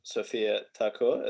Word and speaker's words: Sophia Thakur: Uh, I'll definitely make sure I put Sophia Thakur. Is that pronouncing yeah. it right? Yeah, I'll make I, sure I, I put that in the so.